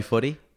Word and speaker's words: Foote: I get people all the time. Is it foot? I'm like, nah, dude, just Foot.